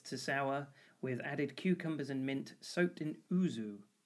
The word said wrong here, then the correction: ouzo: O-U-Z-O. Is that how I'm pronouncing that? Ouzu? O-U-Z-O. – Ouzo.